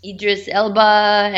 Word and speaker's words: Idris Elba: He's 49 years old, and that's I was checking earlier because I was curious, because there was there was talk about Idris Elba, and I think I'm saying that right. – Yeah, that's right.